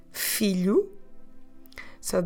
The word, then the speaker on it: filhos: Filhos. On this one, we have that l sound we get with the LH together. So filhos, we spell it in Portuguese, em português, F-I-L-H-O-S.